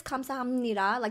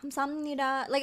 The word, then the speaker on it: 감사합니다: So it is 감사합니다. Like, that's the way to say it. But people do shortcuts in Korea. So it's like 감사합니다. Like, it's like mushed together.